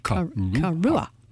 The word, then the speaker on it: Karuah: at Karuha Park. – How do you spell that? – Karuha. – C-A... – No, K-A-R-U-A-H. Karua. – Karua. Okay. – Sounds like Kahlua.